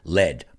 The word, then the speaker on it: lead: Cuidado con esas dos palabras, porque aunque se escriban igual, se pronuncian diferente. En este caso, lead.